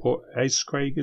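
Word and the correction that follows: Port Askaig: is it? – Port Askeig. – Askeig.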